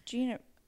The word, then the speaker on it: Gena: How do you pronounce Gina, right? Or is it Jenna? – I think it's Gina. – But there's so many things that I can't do today. – How is it spelled? – G-E-N-A.